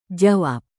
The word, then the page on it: jawab: Indonesian